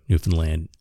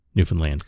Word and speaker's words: Newfoundland: When you emphasize the found, like Newfoundland. – Yeah. – Or when you pronounce it more like Finland, you know, like the country Newfoundland. It's like, it's not Newfoundland, it's Newfoundland, you know.